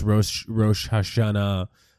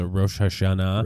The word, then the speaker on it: Rosh Hashanah: Like, well, I'm going off topic really quickly, but I just had an enormous Rosh Hashanah dinner. You know, that's how it's Baruch Hashem. – That's Baruch Hashem. That's how it's pronounced also, Rosh Hashanah.